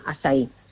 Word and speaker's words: açaí: Así que yo creo que es un producto a tener en cuenta y comprar el local, el açaí orgánico. Si lo queréis buscar escrito, que se escribe A con la cedilla, como si fuera la portuguesa, y se llama eso acai orgánico, y se pronuncia açaí.